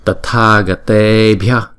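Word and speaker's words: tathāgatebhya: Tathagatebhya, tathagatebhya. Chữ này khó đọc nè. Bhya, chúng ta đọc nhanh.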